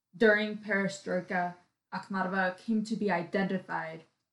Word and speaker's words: Perestroika: Going back to the time of Perestroika, did I say that right? – Yes.